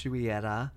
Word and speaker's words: Juillera: So my last name is French. It's correctly pronounced Juillera.